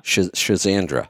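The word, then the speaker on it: Schisandra: And so there was one of the adaptogens, Shizandra, am I saying that right? – Shizandra.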